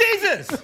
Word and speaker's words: Desus: It's Desus!